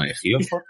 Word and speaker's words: Ejiofor: que siempre me da la sensación de que se llama Alfajor. No, Ejiofor.